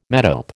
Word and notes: metope: American English

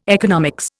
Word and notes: economics: Indian

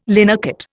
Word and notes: linocut: Indian

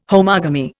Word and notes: homogamy: Indian

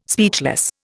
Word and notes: speechless: Indian

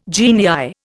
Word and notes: genii: Indian

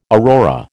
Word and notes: aurora: American English

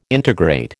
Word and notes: integrate: American English

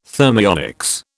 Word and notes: thermionics: British English

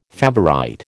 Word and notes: favourite: American English

